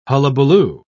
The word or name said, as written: hullabaloo